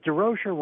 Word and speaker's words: Durocher: And Leo says, no, but Durocher rhymes with kosher.